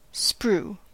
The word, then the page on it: sprue: /spɹuː/